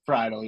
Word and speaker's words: Friedl: 7, TJ Friedl. Is that how you say his name? – Friedl. – That was Friedl, yeah.